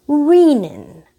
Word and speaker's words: renin: And as a result, they're going to produce an enzyme called renin, R-E-N-I-N. And there are some dogmatic people who will insist that it's pronounced renin, R-E-N-I-N, from renal tissue.